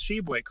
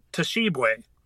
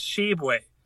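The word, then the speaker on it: Tshiebwe: who pronounced it Toshibwe. That's not Toshib. It doesn't say T-A Shibwe in there. It's not T apostrophe Shib. It's just Shibwe. Oscar Shibwe.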